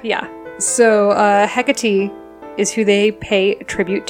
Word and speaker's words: Hecate: Is that how you say it? I always read it Hecate because I don't know things. Just like that's how it made sense. Is it Hecate? – Hecate. – Okay, good to know.